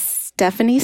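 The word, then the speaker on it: Stefanie: Anyway, my name again is Stephanie Stark, and my IG handle is Stephanie Stark Art. And that is Stephanie spelled with an F, like Frank, not a PH.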